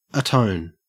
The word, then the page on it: atone: Australian English